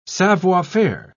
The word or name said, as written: savoir-faire